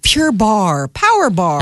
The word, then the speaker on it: Barre: B-A-R-R-E, but it's pronounced Bar.